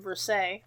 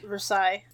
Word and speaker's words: Versailles: and so the trial was held in Versailles. – Versailles. – I know that one. I knew that one and I said it wrong.